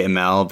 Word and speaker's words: Imel: Yeah, I mean, no one really knows how to say it, honestly. I don't even really know how to say it, but I just say Imel because it sounds fancier.